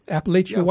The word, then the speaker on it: Appalachian: Am I saying that right? Appalachian white is okay.